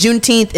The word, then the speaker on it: Juneteenth: But if you don't know what it is, Juneteenth is basically... It's pronounced Juneteenth because it's a combination of June and 19th.